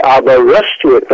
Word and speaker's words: Aberystwyth: I say Aberystwyth, and then you've got it.